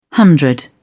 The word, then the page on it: hundred: British English